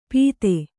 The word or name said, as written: ಪೀತೆ